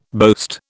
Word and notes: boast: British English

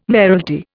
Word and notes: mayoralty: Indian

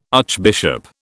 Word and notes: archbishop: British English

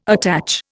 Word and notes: attach: Indian

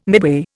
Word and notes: midway: Indian

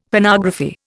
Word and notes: Phonography: Indian

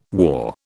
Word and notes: war: British English

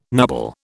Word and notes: nubble: British English

